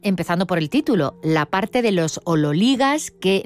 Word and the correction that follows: ololigás: Teóricamente se pronuncia ololigás según la, bueno, pues la transcripción fonética del griego al español,